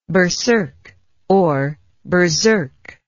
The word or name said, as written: berserkness